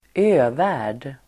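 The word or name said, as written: övärld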